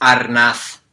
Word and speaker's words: Arnaz: And she and her husband, Desi Arnaz, or I've always heard it said Arnaz, but... – I believe it's pronounced Arnath.